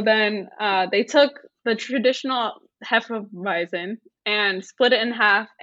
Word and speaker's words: Hefeweizen: Hefeweizen. – That one. Hefeweizen.